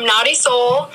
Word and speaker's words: Nautisoul: Naughty Soul. – Spell it for us. – Naughty, as in nautical. – I love it. N-A-U-T-I-S-O-U-L or S-O-L? – Yeah. S-O-U-L.